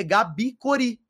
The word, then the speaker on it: Gabi Cori: Agora, eu criei esse nome, cara, Gabi, Gabi Corinthians, e eu coloquei Gabi Cori Só que na pronúncia, como a galera costuma ler, é Gabi Cori, porque era G-A-B, depois C-O-R-I. Então era Gabi, podia ser menina.